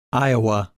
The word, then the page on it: Iowa: English